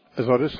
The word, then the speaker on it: Azotus: And then in the 40, it says Philip found himself in Azotus, if I'm saying that right. – Azotus, uh-huh.